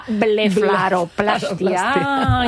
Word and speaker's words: blefaroplastia: Que es, a ver si lo digo bien, que mira que lo he ensayado antes de entrar aquí, porque te la quita la palabra, la blefaroplastia, y lo he dicho mal. – Lo has dicho fenomenal.